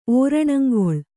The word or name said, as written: ಓರಣಂಗೊಳ್